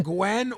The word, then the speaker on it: Nguyen: It's either Gwen or Nguyen. – What is it? – Nguyen. – Nguyen. – Or Gwen. It's not said the way that you spell it.